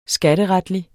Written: skatteretlig

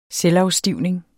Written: selvafstivning

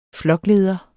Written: flokleder

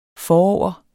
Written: forover

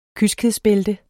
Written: kyskhedsbælte

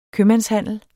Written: købmandshandel

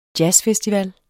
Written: jazzfestival

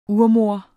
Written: urmoder

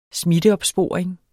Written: smitteopsporing

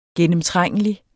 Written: gennemtrængelig

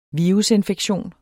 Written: virusinfektion

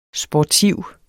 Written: sportiv

